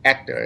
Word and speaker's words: Hector: yeah, but you just don't you don't pronounce the H. Is uh is uh the proper way to do it is Hector is his name, and he's great.